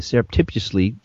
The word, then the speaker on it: surreptitiously: He surreptitiously, sneakily, stealthily, man, I'm just I even wrote that word out so I could say it right.